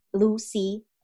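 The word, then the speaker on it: Lucea: So my name is spelled as Lusea. Lucy, like the ocean.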